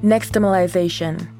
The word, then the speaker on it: nixtamalization: Nixtamalization.